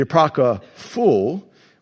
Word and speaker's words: apocryphal: The manuscripts have been classified into three main categories: biblical, the apocryphal, which sounds like I'm saying it wrong, I double-checked, and the Secretarian.